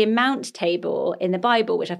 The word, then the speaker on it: Tabor: So Tabor, which they have as their middle name, is spelled T-A-B-O-R. – Okay, so I said that wrong. – Well, it's really nice. It could be Tabor. I like Tabor as well, actually.